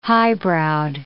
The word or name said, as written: highbrowed